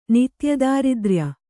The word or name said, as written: ನಿತ್ಯದಾರಿದ್ರ್ಯ